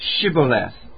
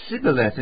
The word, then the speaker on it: Shibboleth: If he said no, they would say, then you got to say this word, Shibboleth. And they couldn't say it. They said Sibboleth instead. He couldn't pronounce it right.